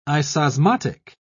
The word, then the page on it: isosmotic: British English